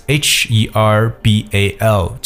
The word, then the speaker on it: herbal: 中草药叫做 herbal medicine，可是 herbal that's H E R B A L 这个单词特别注意一下，H 是不发音的，所以直接读成为 herbal medicine 中草药。